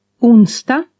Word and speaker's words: onsdag: D is pronounced T. – Onsdag.